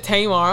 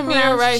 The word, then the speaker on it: Tamir Rice: Oh, Tamir Rice. Yeah. You said Tamar. I'm like, what am I? – My bad, my nigga. – Tamir Rice.